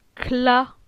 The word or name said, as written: Cla